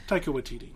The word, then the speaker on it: Taika Waititi: Taika Waititi. I transposed some syllables there. – Yeah. – Taika Waititi.